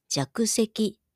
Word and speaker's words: 若石: 若い石と書いて弱石と読みます。